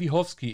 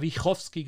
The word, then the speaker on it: Wiechowski: unter thinknatalia zu finden und natürlich auf LinkedIn unter Natalia Wichowski. Ich habe jetzt extra den Namen richtig aussprechen gelernt, weil ich habe vorher mal Wichowski genannt.